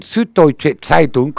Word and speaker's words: Süddeutsche Zeitung: Y ella dijo hace poco, en el Süddeutsche Zeitung, un diario de Alemania, Süddeutsche Zeitung.